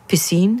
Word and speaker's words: piscines: They have a few piscines. Is that how the French pronounce swimming pools? – It is.